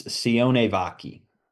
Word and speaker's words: Sione Vaki: Like, I think David Montgomery is going to have a phenomenal Let's go Sion Vaki. – Sion Vaki. As the owner of this man, how do you pronounce his name? – It's Sione Vaki.